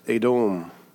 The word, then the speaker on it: Edom: And it's actually pronounced Edom in the Hebrew.